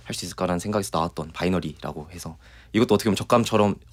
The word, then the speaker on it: 바이너리: 곡 제목은 저희가 이제 바이너리라고 해서 사람들이 봤을 때는 비나리라고 읽히잖아요. – 아. – 네. 근데 원래는 바이너리고 예.